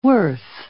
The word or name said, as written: worth